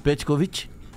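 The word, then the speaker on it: Petković: O Petkovic eh, estoura no Brasil. – Ah, isso aí. Peraí, pronuncia é, o nome de novo, como é que é? – Petkovic.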